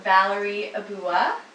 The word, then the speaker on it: Valerie Ebuwa: Today, we are talking with Valerie Ebuwa.